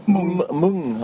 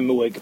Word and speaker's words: Mwng: How do you pronounce that album? – Mung. – Mung. – Yeah, I was going to say Mwig, but... – M-W-N-G. – Mung.